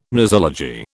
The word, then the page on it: nosology: British English